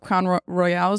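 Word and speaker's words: Crown Royal: So last night I brought on a bottle of Crown Royale.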